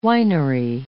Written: winery

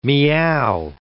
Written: miaow

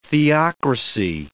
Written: theocracy

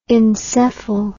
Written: encephal

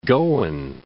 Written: Goan

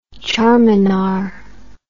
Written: charminar

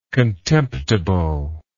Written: contemptibly

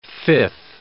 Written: fifth

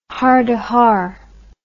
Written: hardehar

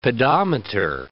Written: pedometer

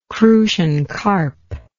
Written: crucian carp

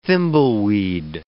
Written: thimbleweed

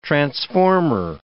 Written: transformer

